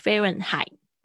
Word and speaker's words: Fahrenheit: 它是比较特别的是 H，它这个拼字有 H，但 H 不发音。 Fahrenheit，All right，然后后面有一个 T 的声音。 Fahrenheit。